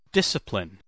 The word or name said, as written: discipline